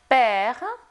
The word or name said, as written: père